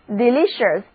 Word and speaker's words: delicious: 那如果我们把 de 这个表示加强意义的前缀放在词根 LIC 精美前面，再把形容词后缀 IOUS 摆在词根后面，那得到的单词就是 delicious， delicious， D-E-L-I-C-I-O-U-S，表示美味的，宜人的。字母 E 和第一个字母 I 的读音都是短而且急促的 E，重音第二个音节。